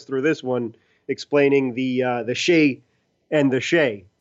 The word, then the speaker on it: Skjei: He was interviewing a player and a Ranger, and he was trying to figure out how to pronounce the guy's name, which was S-K-J-E-I, and he just gave up. That was pronounced Shea.